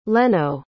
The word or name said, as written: lenno